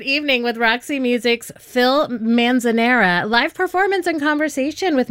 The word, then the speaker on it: Manzanera: Manzanera.